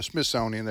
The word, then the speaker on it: Smithsonian: Yeah, Smithsonian.